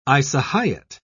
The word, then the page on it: isohyet: British English